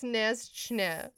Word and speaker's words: Snezhnaya: I wrote down how to pronounce it. The remote Snezhnya. – What does it say? – It's like that, but it's pronounced Snezhchne.